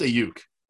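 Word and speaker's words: Aiyuk: I don't know why I said Ayuk. – It's Ayuk. Yeah, that was a weird pronunciation.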